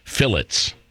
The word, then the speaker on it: fillet: Or as the English say, do you know how they pronounce filet? Fillets. They call it a fillet.